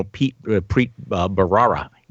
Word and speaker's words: Preet Bharara: It was other folks in the Justice Department that were responsible for the prosecution of, well, it was, you know, Preet Bharara. Did I pronounce that correctly? – Yes.